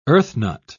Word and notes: earthnut: British English

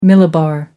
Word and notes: millibar: American English

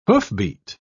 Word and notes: hoofbeat: British English

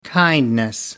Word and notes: kindness: American English

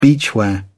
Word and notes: beachwear: British English

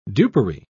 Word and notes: dupery: British English

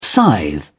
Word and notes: scythe: British English

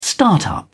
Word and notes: start up: British English

